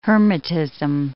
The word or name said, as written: hermitism